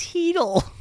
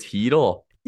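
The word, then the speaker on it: Chytil: Isn't that how you say it? – It's Heedle. – It's Heedle. – Yeah. – You show me a linguistics breakdown how that's he. Oh, I guess there's the C is silent, hey?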